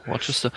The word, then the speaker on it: Worcester: Worcester oder Worcester? Also ich glaube, es wird Worcester ausgesprochen. – Worcester. Wie die Soße fürs Grillen.